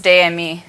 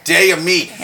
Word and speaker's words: Des Amis: I think it's pronounced Des Amis. – Des Amis. Yeah, see, that's the thing. They like consonants. They don't like saying them, though. You know, they like writing them, don't like saying them out loud.